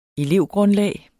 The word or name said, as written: elevgrundlag